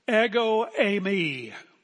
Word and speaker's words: ego eimi: He said that you may believe, ego eimi, ego I, eimi, am.